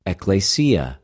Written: ἐκκλησια

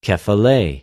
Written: κεφαλη

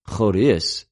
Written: χωρις

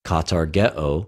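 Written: καταργέω

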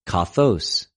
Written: καθως

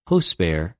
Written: ὡσπερ

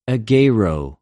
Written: ἐγειρω

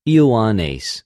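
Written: Ἰωάννης

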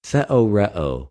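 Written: θεωρέω

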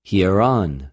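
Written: ἱερον

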